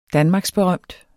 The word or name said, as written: danmarksberømt